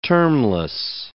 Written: termless

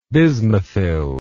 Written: bismuthyl

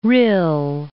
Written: Rhyl

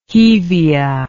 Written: hevea

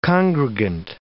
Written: congregant

